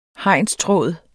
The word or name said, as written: hegnstråd